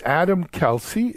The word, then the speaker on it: Adam Kalsey: And then last but not least, as a panel member today, is Adam Kalsey. Is that pronounced right, Adam? – It is, absolutely, yes.